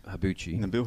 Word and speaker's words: Nabil: Nabil Habbouche.